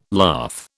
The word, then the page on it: lath: British English